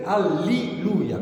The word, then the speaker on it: alliluia: Una raccomandazione nell'allegro finale, la pronuncia è a min e alliluia, con la I e non con la E. – Alleluia.